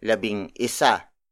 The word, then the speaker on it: labing isa: So it is pronounced as labing isa, not labing isa.